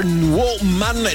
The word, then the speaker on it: Wo, Man: Peggy Goo, you're a star, woman. Sì, lo dico così, lo pronuncio così perché è scritto staccato, W-man, insomma, è un doppio senso.